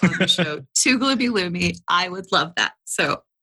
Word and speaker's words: Lumi: At Gloomy Loomy. Gloomy, just like it's spelled, L-U-M-I.